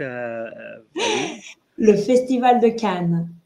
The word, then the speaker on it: Le Festival de Cannes: Le Festival de Cannes.